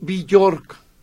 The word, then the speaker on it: Björk: Que no es Björk, ya me dijo Meche que no se dice Björk. Yo también decía Björk, ¿eh? – ¿Cómo se dice? – Es Björk. Ah. Björk es como se pronuncia.